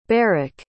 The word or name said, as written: barric